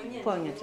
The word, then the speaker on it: poignant: How do you pronounce that? – Poignant, hein, c'est ça.